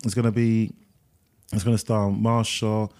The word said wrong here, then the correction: Mahershala: I always get his name wrong, Mahershala Ali. – Yeah. – Yeah, Mahershala Ali.